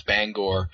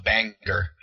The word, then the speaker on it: Bangor: Yeah, there was one, the one that I hear a lot is like, especially people talking about Stephen King and stuff, they'll pronounce Bangor as, Banger. And, so that one always drives me crazy too. Yeah. It's not even spelled that way. Like I could see if it was an ER, but it's not.